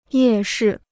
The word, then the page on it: 夜市: yè shì